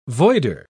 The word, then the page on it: voider: British English